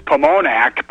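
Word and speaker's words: Pomonok: they have said it's Pomonac, but it's spelled with two O's, but that's how you guys talk.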